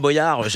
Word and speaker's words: Boyard: Non, ouais, c'est Boyard. Frère, on devrait dire Boyard. – Ah, on dirait Boyard, j'avais raison. Oui. Boyard comme Boyau, voilà.